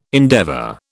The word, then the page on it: endeavour: British English